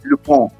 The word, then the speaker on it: Lupin: Hoy voy a hablar de la segunda temporada de Lupin, así como se llama en francés. Lupin, se escribe Lupin, para nosotros que leemos en español y, pensamos en español.